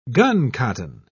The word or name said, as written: guncotton